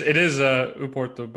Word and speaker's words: Porto: By the way, do you call it Porto or O Porto? – Porto, but you're pronouncing it probably much better than I am. So I mean, it is Uporto, but Uporto, yeah, right? At the same time, I guess everyone here calls it Porto or Porto.